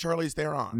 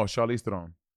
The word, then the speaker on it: Charlize Theron: Well, you're not even pronouncing her name right. – I think it's Charlize Theron. – No, Charlize Theron.